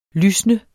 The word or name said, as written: lysnet